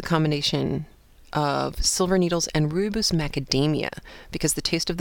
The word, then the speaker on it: rooibos: And she said that she particularly likes to combine the silver needles with rooibos. I don't know if I'm pronouncing that right. It is R-O-O-I-B-O-S.